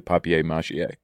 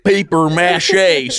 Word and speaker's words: papier-mâché: It's French. Papier-mâché. It's like a French word. – Yeah. – Papier mâché. – Paper mâché. Sorry, I'll pronounce it your way.